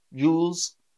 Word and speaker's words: jewels: So I say it as one syllable, jewels. But old-fashioned people might say jewels.